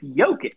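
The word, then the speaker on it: Jokić: But it's his name is a soft J. It's Jokic.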